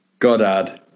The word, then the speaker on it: Goddard: How do you pronounce the word G-O-D-D-A-R-D? – Godard. – Eric, how do you, as an American by birth, how do you pronounce G-O-D-D-A-R-D? – Goddard. – Same here, Goddard. So Goddard.